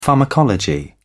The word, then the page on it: pharmacology: British English